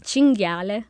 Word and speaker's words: cinghiale: Cinghiale.